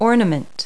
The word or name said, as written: ornament